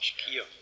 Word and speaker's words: Schio: Es hat hier ganz in der Nähe in Schio, spricht man das so aus? – Schio.